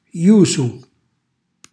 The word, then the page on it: jusu: Calabrese